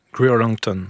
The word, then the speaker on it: Greer Langton: Et la dernière découverte, c'est l'artiste, je ne sais pas si je prononce bien, Greer Langton. G-R-E-E-R Langton. – Oui, Greer Langton. – Greer. – J'affirme ça sans être sûr de la prononciation.